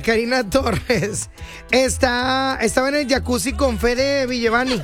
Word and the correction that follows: Vigevani: Sí se pronuncia así, ¿verdad? Vigevani. – Ay, wow. – Una disculpa, Fede Vigevani.